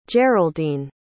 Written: geraldine

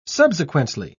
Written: subsequently

